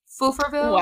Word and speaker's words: Stouffville: So it's called Stouffville. – I was right. I was so right. – But it is spelled Stouffville. And so if you speak English, it looks like Stouffville.